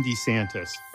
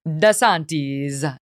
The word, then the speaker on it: DeSantis: He points out that the proper Italian pronunciation would be DeSantis, but a lot of Italians make it a little bit more Americanized and might just go with DeSantis.